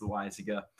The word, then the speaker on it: Loaiza: No, you would think lasagna, with the last name like Lasagna, but it's not pronounced Lasagna. It's Loaiza.